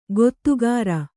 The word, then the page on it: ಗೊತ್ತುಗಾರ: gottugāra